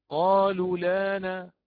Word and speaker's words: قالوا الآن: أقول قالوا لانا، ليست قالوا لانا، هذا الحرف المد، هذا الواو التي تلي اللام المضمومة، هذا الحرف لا يعود معي وآتي به، لا يعتد به، فيبقى حتى ولو تحركت اللام هنا بالنقل فيبقى كذلك حرفا محذوفا لا يتلفظ به، يرسم نعم ولكن لا يتلفظ به.